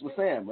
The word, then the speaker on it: Wissam: I think it's Wissam. W-I-S-S-A-M. Wissam? That's Wissam, right? – Wissam. – Wissam Al-Manna.